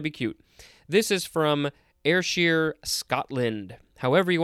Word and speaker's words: Ayrshire: All right, next we have Ayrshire, or Asher. I think all of those are fine. It is spelled capital A-Y-R-S-H-I-R-E.